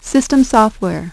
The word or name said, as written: system software